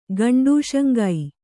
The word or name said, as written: ಗಂಡೂಷಂಗೈ